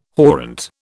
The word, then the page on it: horrent: British English